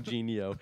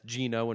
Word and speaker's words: Gino: I like how you call him Genio. Just because it's spelled that way. Have you learned nothing from our Barkevious talk to know that that's Gino and not Genio? – Well, that is a weird way to spell Gino.